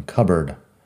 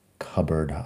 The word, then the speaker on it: cupboard: Pero juntando las dos palabras cambia la pronunciación, cupboard, cupboard, armario de cocina, armario de baño.